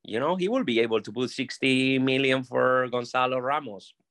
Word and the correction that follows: Gonçalo: Gonçalo. Say it in Portuguese. – How do you say? – Gonçalo. – Gonçalo. Okay.